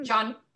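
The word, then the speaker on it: Gian: But this is, I think, our first time we see the magic mirror, who is, how do you say his first name? Is Jean? – John. It's John Carlo.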